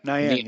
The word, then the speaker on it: Niang: And that takes me to Niang. Did I say that right? – Yeah, Niang. Yep.